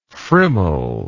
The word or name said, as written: friml